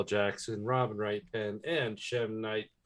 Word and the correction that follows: M. Night: M Night.